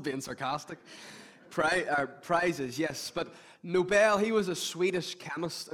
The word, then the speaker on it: Nobel: It's not Nobel, it's Nobel.